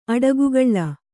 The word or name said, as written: ಅಡಿಗೆಕಲ್ಲು